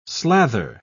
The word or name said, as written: slather